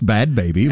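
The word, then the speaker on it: Bhad Bhabie: I think it's pronounced Bad Baby, but it's spelled...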